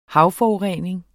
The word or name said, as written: havforurening